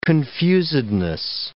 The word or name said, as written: confusedness